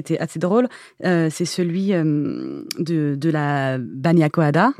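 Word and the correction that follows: bagna cauda: La banya cauda.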